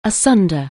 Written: asunder

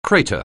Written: crater

